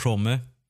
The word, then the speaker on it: Homme: George Homme. Alltså hur uttalas hans efternamn egentligen? Jag tänker att det är ett stumt E.